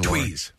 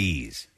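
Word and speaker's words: Tewes: So happy birthday to, how do you say her last name again? – Tweez. – Lauren? Tweez. It's T-E-W-E-S.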